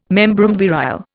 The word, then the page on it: membrum virile: Indian